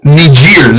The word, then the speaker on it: Niger: Niger. Or Niger, however you want to say it. Hey, Don, welcome to the show. – Well, thanks. Yeah, it's the Niger that I went to. – Niger.